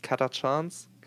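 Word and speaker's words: Catachan: and the what is the faction called or pronounced? Katachans? – Katachan, yeah.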